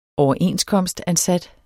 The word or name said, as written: overenskomstansat